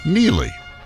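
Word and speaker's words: Neagley: I love the Reacher universe, which is why I watched Neely. – Oh, yeah. – It looks like Neely, but the G is silent.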